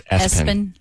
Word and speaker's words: ESPN: Pronounced Espen. – Espen, yeah. No way.